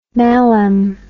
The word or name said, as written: mallam